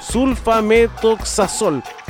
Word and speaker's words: sulfametoxazol: Dentro de estos grupos, dijeron, de bacterias identificamos resistencia a antibióticos como ampicilina y sulfametoxol. Lo dije bien, ¿no? Sulfametoxazol. Ahí está.